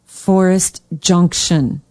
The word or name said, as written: Forest Junction